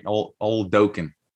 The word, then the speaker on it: Oladokun: Oladokun.